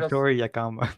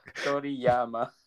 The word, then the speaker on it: Toriyama: I think you said Toriyama. – Toriyama. – Toriyama, yeah. Sorry. I'm looking at the Google right now. I'm like, what is that? What is that? – Sorry. Akira Toriyama.